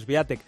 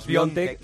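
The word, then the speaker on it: Świątek: Bueno, a mí cuando me dijeron que Sviatek se pronuncia Sviontek, dije, pues igual tiene algo que ver esto.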